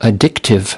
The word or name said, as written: addictive